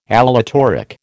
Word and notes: aleatoric: American English

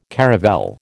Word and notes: caravel: American English

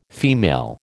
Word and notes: female: American English

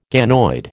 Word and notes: ganoid: American English

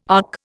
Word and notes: ark: Indian

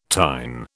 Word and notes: tine: British English

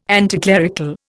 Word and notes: anticlerical: Indian

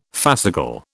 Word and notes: fascicle: British English

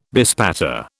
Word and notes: bespatter: British English

